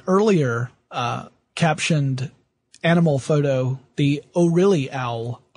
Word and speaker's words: O RLY: So it looks like it's O'Reilly. That's yes. But it's spelled O RLY.